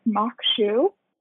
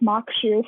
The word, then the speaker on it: maque choux: I have never heard of this before, but it's mock shoe, and it's spelled M-A-Q-U-E-C-H-O-U-X, and pronounced mock shoe from what I can tell looking it up on the internet.